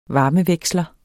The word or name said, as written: VARMEVEKSLER